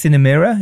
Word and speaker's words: Cinemira: I think it's pronounced Cinemira, is that right? – Yes.